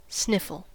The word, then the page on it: sniffle: American English